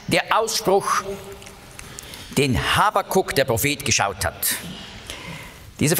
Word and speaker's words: Habakuk: Habakuk, so spricht man das auf Hebräisch aus, ist ein so schöner Name.